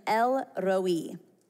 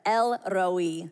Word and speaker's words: El Roi: If you can roll your R's, really go for it here. But El Roi, say it one more time, El Roi. I said this wrong for the last million years. I thought it was like El Roi.